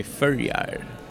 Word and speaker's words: Føroyar: We're not Danish, it's under Denmark, but you say it in Faroese, say Føroyar.